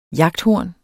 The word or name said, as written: jagthorn